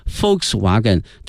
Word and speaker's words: Volkswagen: 首先看一下大众哈 ,VW, 它的全称呢是 Volkswagen, 因为在德语里面这个 Volks, 啊注意一下这个 V, 啊它的发音呢是 F 音哈 ,Volkswagen, 它表示人民的意思 ,people,Wagen 呢是车 ,Volkswagen 这个词德语呢翻译成英语呢是 people's car, 翻译成中文呢是大众。